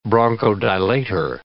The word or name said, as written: bronchodilator